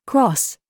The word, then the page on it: COOK: British English